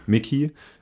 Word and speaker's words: Mikey: Ich habe auch gehört, dass, ich weiß nicht, spricht man sie Mikey Madison oder Mickey Madison aus? – Mickey.